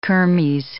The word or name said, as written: kermes